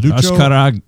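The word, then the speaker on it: Azcárraga: Luco Azcaraga. And I'm probably not pronouncing it. No, it's Lucio. – Lucho. – Ashkaraga. – Ashkaraga. Ashkaraga.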